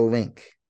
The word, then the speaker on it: LINQ: We stayed at the Link. That's spelled LINQ, okay?